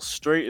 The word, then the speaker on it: Strait: Well, Straight is my last name. – Mm-hmm. – And the 88, I was just like, fuck it. Cool number. Fun number. Rhymes with my last name.